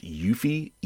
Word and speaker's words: Eufy: I replaced it with the, I think it's pronounced Eufy, E-U-F-Y, which is an offshoot of the Anker brand.